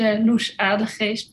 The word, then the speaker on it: Loes Adegeest: It's Loes Adegeest, but I know it's hard to pronounce.